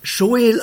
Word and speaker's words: Joel: Dieser Schreiber hat sich den Namen Joel gegeben, und dieser Name in der Schweiz ist es ein bekannter Vorname, der dann Joel ausgesprochen wird.